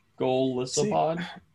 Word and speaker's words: Golisopod: Is that how you say it? Golisopod? Golisopod? – Yeah.